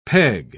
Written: peg

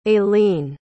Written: aileen

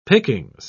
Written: pickings

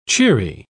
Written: cheery

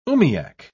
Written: umiak

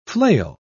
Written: flail

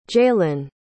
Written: jaylynn

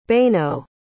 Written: Beno

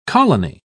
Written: colony

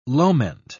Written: loment

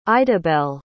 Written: idabelle

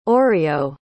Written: oreo

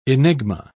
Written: enigma

